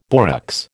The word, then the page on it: borax: American English